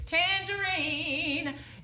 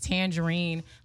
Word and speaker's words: Tanjareen: My name is Tangerine. It is not spelled like the fruit. It's spelled T-A-N-J-A-R-E-E-N.